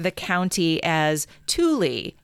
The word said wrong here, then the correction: Tooele: spelled T-O-O-E-L-E. Apparently, we should have been saying Tooele.